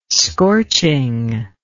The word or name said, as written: scorching